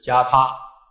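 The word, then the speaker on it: gāthā: 不然的话，梵文发音是加他，如果是为了纯略的缘故，就应该翻作加字，而不是翻译作记字。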